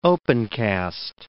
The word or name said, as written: opencast